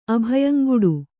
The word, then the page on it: ಅಭಯಂಗುಡು: kannada